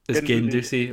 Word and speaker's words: Guendouzi: Guendouzi, okay.